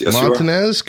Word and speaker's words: Montanez: Yes, Montanez, you are.